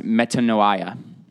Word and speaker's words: metanoia: The word that is used here is pronounced metanoia.